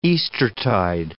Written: Eastertide

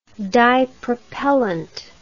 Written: dipropellant

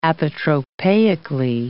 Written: apotropaically

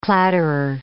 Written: clatterer

